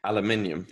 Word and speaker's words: aluminium: How do you say aluminum? – Aluminium. – Yes. Yeah. So like there's so many extra letters and syllables in that word.